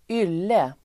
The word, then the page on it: ylle: [²'yl:e]